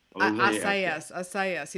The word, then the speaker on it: Assayas: Say his name, Olivier Assayas. – I can't, because I sound bad pronouncing it. I just sound like American. – Assayas.